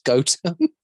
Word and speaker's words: Gotham: it's pronounced Gotham. Doesn't does not surprise me. Yeah, literally, all the locals call it Gotham.